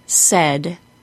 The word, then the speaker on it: said: The words says and said are pronounced with a short E sound.